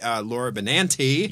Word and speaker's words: Benanti: And later, Laura Benanti. – Yes. – Who I've been... – You just pronounced it correctly.